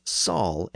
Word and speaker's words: shall: Very often, when a word began with an sh sound, northerners would convert it into a simple s sound. So a word like shall was often pronounced as sal in the north, and that's the way the word is rendered here in John's northern speech.